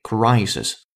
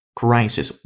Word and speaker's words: crisis: 最后那个单词叫 crisis，这个单词如果是我们老朋友的话，一定知道我们要强调的是这里边第一个字母的发音，它是双元音 i，于是叫 crisis，not crisis。 It should be crisis, crisis 危机啊，这个是你自己在说的时候一定要注意的问题。